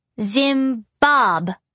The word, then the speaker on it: Zimbabwe: How do you pronounce the last country? I asked. The one that starts with a Z. Zimbabwe, she said, sounding it out like it was three words.